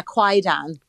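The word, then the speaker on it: Kwaidan: Quaidan, is that what the name of the movie is? – Yeah, Quaidan.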